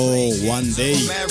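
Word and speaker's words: One Dae: Un MC de Brooklyn, Nueva York, llamado One Day. Lo de One escrito como normal, como si O-N-E. Y lo de Day se escribe D-A-E.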